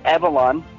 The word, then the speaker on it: Evalon: So, Evalon, you had it right.